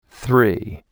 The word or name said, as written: Three